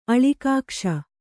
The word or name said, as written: ಅಳಿಕಾಕ್ಷ